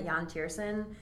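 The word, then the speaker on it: Yann Tiersen: I want to I think I'm pronouncing the name right, Jan Tiersen.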